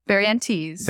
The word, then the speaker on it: Barrientez: Did I pronounce it correctly? – That works. – I don't know if I did. Barrientez? – Barrientez. – Barrientez. – There you go.